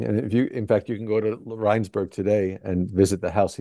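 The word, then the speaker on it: Rijnsburg: Did he moved to Regensburg, right? – Rheinsberg, yes. Yeah, it's the Dutch pronunciation.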